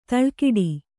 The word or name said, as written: ದಳೆಕಟ್ಟು